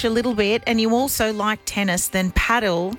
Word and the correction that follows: padel: Yeah, but sorry, can I just stop you? It's padel.